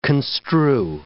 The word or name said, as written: construe